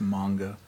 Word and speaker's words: manga: It's manga. – Manga? – Mm-hmm. Yay, we said it wrong together. Woo! – The correct term is manga.